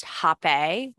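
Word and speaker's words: Hapé: It can be pronounced Hape, which is how I say it, with an accent over the E, or Rape, R-A-P-E, apostrophe over the E.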